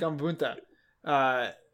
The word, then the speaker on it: Gamabunta: Gamabunta? How do you pronounce his name? – Gamabunta. – Gamabunta.